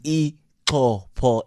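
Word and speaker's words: Ixopo: Let me tell you, that town is called Ikopo. It's not Ikopo or Izopo, or it's Ikopo.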